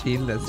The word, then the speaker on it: Gilda: Gilda, sí.